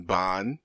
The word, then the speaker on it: Bohn: But today we are discussing the interesting life and experiences and violence of one Jason Bohn, which I was mispronouncing as Bone forever until people told me I was wrong, i.e.